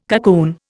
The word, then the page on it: cocoon: Indian